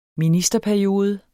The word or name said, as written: ministerperiode